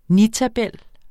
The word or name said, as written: nitabel